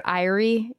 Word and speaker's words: Aire: It's at the Air Ancient Baths, which I think is technically pronounced Airy. – Yeah. A-I-R-E.